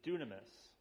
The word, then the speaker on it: dunamis: The Greek word for power is dunamis. That's how you say it, dunamis. That's the old Greek word.